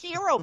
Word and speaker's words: gyro: It's not pronounced gyro. It's pronounced hero, babe.